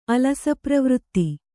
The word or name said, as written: ಅಲಸಪ್ರವೃತ್ತಿ